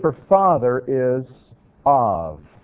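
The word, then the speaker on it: av (אב): This letter, bet, can be pronounced as a hard B sound or as a V sound, av.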